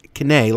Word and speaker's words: Kene: I actually corresponded with the listener over the last few days, and she let me know that her name is pronounced Kene, like Renee, but with a K.